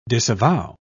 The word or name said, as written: disavow